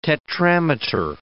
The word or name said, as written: tetrameter